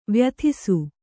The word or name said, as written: ವ್ಯಥಿಸು